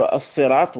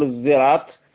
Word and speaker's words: الصراط: كأنه ينطق ليس صاد يقول الصراط ولا يقول الزراط وإنما يقول الصراط هكذا، كأنه يعني يشم صاد صوت الزاي، هذا أيضا يطلقون عليه إشمام.